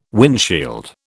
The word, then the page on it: windshield: British English